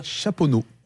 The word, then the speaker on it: Chaponost: Chaponneau. Chaponneau, on ne prononce pas le OST.